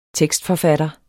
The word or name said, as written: tekstforfatter